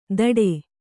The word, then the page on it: ಧಡೆ: Kannada